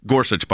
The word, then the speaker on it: Gorsuch: I'm going to threaten you, Justice Gorsuch, by the way, is how it's pronounced.